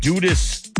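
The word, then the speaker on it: Dudus: Sources say he got into it with Jamaican drug lord Christopher Dudas Coke. That's how you pronounce it.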